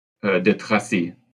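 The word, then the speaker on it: de Tracy: So destitute de tracé, is that right pronunciation? – De tracé. – Tracé. Okay.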